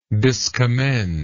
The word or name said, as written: discommender